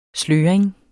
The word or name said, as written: sløring